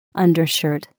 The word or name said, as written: undershirt